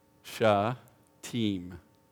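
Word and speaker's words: Shittim: The actual pronunciation of that word is sha-team. Okay, everybody try it. Sha-team. Sha-team. Yes.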